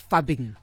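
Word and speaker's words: phubbing: You know, they say it's fubbing. – Fubbing. – It's like a combination of phone and snubbing.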